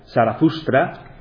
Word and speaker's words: Zarathustra: Bien, el nombre iranio de Zaratustra, que en lengua original se pronunciaría Zarathustra, si veis el ratón, aquí aparece en un texto avéstico, este sería el nombre de Zarathustra.